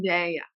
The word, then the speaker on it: Zendaya: Zendaya. – Oh, okay. Thank you. – How do you say it again? – Zendaya.